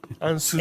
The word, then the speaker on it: Ansuz: Ansys. – A? And, what's the spell? – A-N-S-U-Z. – A-N-S-U-Z.